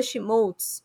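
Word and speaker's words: Schmolz: E lá nos três vocês me encontram no arroba ou barra Fernanda Schmoltz. E lembrando que meu sobrenome se escreve S-C-H-M de Mickey O-L-Z.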